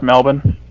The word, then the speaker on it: Melbourne: It's pronounced Melbourne.